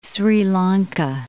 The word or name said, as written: Sri Lanka